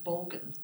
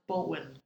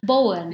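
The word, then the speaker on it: Borgen: The Brits pronounce it Bolgan. – Yeah. – It's actually pronounced Bolwyn. – Bolwyn. – Yeah, that's how you say it.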